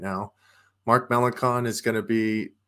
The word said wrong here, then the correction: Melancon: – I feel like I said that wrong. Chris, Melancon. – Tell me. Melancon.